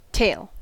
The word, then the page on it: tail: American English